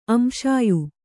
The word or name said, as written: ಅಂಶಾಯು